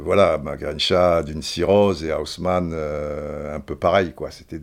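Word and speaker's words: Houseman: Je dis mal, je la prononce à l'anglaise. Ça, s'écrit house comme maison, man comme un homme, Haussmann, l'homme de la maison.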